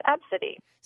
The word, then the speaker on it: Abcde: Well, and you would think it would just be called A-B-C-D-E. – Right. But it was pronounced Absidy.